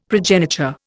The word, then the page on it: progeniture: Indian